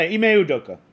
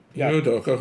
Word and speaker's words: Ime Udoka: Yeah, Ime Udoka. – Ime Udoka,